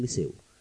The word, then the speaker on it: Liceu: Es una película en la que el escenario que van a compartir es el Gran Teatro del Liceo, o como dirían en catalán, el Gran Teatro del Liceu. Creo que se pronuncia así, ¿no?